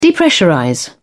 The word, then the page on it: depressurize: British English